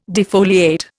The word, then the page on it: defoliate: Indian